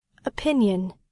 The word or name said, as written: opinion